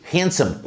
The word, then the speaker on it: handsome: Essa letra D handsome quase que não é pronunciado.